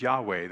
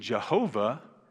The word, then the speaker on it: Yahweh: And if you come to this church, you know when I see the all capital Lord, it's behind it is Yahweh, the four consonants that you see there in your notes. It's called the Tetragrammaton, four letters. And the pronunciation Jehovah is a misunderstanding.